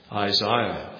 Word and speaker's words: Isaiah: Well, we go to the prophet Isaiah, for example. Or as it should be properly pronounced, Isaiah. But Isaiah, because I can speak, I'm bilingual.